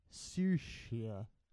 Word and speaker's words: Saoirse: All right, here, let me correct your pronunciation because that's a weird name. It's Saoirse.